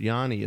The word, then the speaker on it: Yoni: And by the way, they spell that Y-O-N-I. – It's almost Yanni.